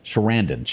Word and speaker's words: Sarandon: Sarandon.